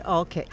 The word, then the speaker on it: Olcott: I said Olcott, which is the way the locals pronounce it. – Ah. – And that's how you can tell a tourist from a local out here. If you pronounce it the way we do, it would be A-L-L-K-I-T.